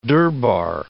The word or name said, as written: durbar